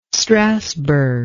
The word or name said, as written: strasbourg